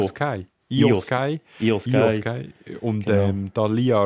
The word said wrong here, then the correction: Rhea: Ripley. – Rhea Ripley. – Rhea ist schon letztes Mal falsch gesagt. Rhea Ripley.